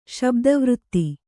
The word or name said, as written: ಶಬ್ದವೃತ್ತಿ